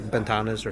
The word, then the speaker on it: Ventanas: I think you pronounce the V like with a B, like Ventanas or something.